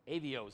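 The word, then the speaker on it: Arioch: Avio is how you pronounce that. Isn't that weird? Don't look like it.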